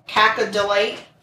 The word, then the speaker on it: cacodylate: It's cacodylate.